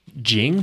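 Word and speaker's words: Ging: Interestingly, the father's name is Jing, but it's spelled with a G.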